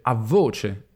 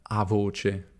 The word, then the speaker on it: a voce: Per questo anche in italiano moderno standard si dice a voce e non a voce, pronunciato senza raddoppiamento della V.